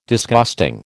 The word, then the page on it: Disgusting: American English